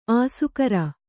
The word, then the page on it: ಆಸೆಕಾರ: kannada